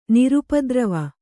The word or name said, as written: ನಿರುಪದ್ರವ